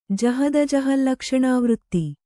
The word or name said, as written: ಜಹದಜಹಲ್ಲಕ್ಷಣಾವೃತ್ತಿ